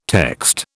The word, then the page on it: text: British English